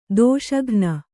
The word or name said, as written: ದೋಷಘ್ನ